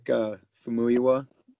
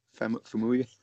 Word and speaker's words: Famuyiwa: This was one of the Rick Famuyiwa. Am I saying that right? Famuyiwa. – I think so. Yeah.